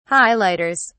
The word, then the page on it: highlighters: British English